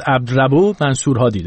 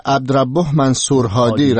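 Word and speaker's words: عبدربه منصور هادی: اتفاقا تو رادیو فردا ما اسمش به شکل مختلف تکرار میکنیم، بعد نیست بگی دقیقاً تلفظ دقیق اسم رئیس جمهور یمن چی است. عبدالرب منصور هادی، درسته؟ – بله، عبدالرب منصور هادی رئیس جمهور یمن.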